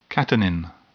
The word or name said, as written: catenin